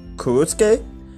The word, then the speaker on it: Kurosuke: after seeing online images from the children's book Kurotsu, Ku, Kurotsuke. It's it's spelled K U R O S U K E by Kuruso Yūshio in 2009.